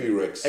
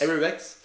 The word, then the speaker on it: Avirex: Awirex, wie spricht man das? Avirex. Avirex. Popnisse.